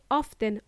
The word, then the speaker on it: often: However, if you want to be proper, as is the case in British English, it should be pronounced as often. T is silent. And if you happen to say often, I will forgive you.